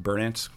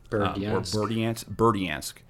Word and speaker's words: Berdyansk: I think it's pronounced Berdyansk. – Berdyansk, and then Oshakov.